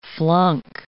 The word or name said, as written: flunk